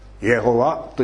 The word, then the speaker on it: エホバ: でも読まなければいけないっていうことで、まあそんなに古くはない数百年前だったと思いますけれども、ヘブライ語の聖書の発音記号 4文字の発音記号を別の言葉の発音記号で賄った。アドナイっていう、主というその言葉の発音記号をあてがってエホバ、イエホワという発音になったんだそうです。